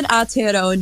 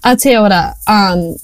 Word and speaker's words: Aotearoa: I live in Aotearoa, New Zealand. – Aotearoa.